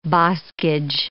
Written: boskage

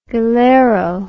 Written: galero

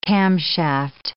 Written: camshaft

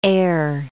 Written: eyre

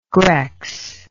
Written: grex

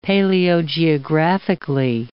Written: paleogeographically

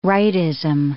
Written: rightism

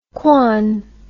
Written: quan

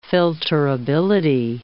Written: filterability

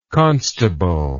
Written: constableship

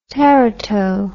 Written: terato

